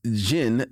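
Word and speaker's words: Djinn: Before reading Masters of Jin, the D is silent.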